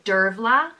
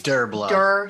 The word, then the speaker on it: Dervla: But here's the actual pronunciation. – The next name is Dervla, which means daughter of the poet. And it's it is Dervla. That's so... – But no, it's with the V, Dervla.